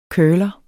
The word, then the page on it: curler: betydning 1: [ ˈkɶːlʌ ]; betydning 2: [ ˈkœːlʌ ]